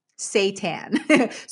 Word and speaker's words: seitan: Seitan, not Satan, seitan. So that's spelled S-E-I-T-A-N.